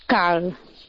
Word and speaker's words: cal: El sonido de la L en cabécar es distinto del sonido en español. – Cal, cal. – Por eso yo no lo pronuncio cal, sino cal. Pronuncia la L.